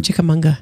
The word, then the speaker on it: Chickamauga: And you're sure that's how we pronounce it? – I checked. – Okay, because I've been mispronouncing it all weekend. – Have you been adding an N? – I have. – Yeah. – Chickamauga. – There you go. No N. No unga.